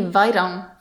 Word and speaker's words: Vairão: And how would you say it? – Verão. – Right, verão. And so that's a sound that's a little bit difficult to make for English speakers because we don't have it, that final vowel sound. – Actually, it'd be vairão, wouldn't it? V-A-I. Okay, vairão.